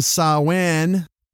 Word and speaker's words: Samhain: Hurricane Sawin. The actual pronunciation of Samhain.